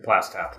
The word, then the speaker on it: Plaistow: Is that how you say it? – Plaistow. – Plaistow, sorry.